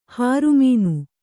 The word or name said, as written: ಹೊರಮುನಿ